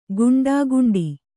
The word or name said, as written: ಗುಂಡಾಗುಂಡಿ